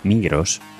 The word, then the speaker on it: Migros: El grupo Migros, sí, sí, ya digo que voy a pronunciar la S en español.